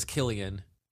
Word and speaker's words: Cillian: I thought his name was Killian, but it's spelled C-I-L-L-I-A-N.